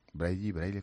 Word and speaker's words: Braille: Braille, ¿cómo se dice? – Braille.